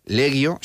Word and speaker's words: legio: Legio, se pronuncia legio en latín clásico, no legio, por favor. Legio, legio septima gemina, es el, así hay que decirlo.